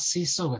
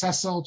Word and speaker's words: Cecil: I've mentioned many times Cecil Roth. And as any Englishman knows, it's not Cecil, it's Cecil, just like Rhodes, Cecil Rhodes.